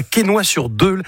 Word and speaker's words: Quesnoy-sur-Deûle: Tu habites à Quesnoy-sur-Deûle. Est-ce que je l'ai bien prononcé? J'ai pas écorché le nom? – Impeccable.